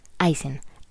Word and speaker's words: Aicen: Aysen, A-I-C-E-N. Aysen es la pronunciación de hierro en alemán. Y yo lo escribo como se pronuncia.